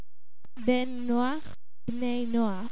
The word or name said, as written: Bnei Nokh